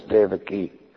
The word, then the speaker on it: Devaki: Yeah, it's the proper pronunciation is Devaki. Because the I is long, not the A.